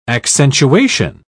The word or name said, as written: accentuation